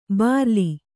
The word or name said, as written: ಬರ್ಲು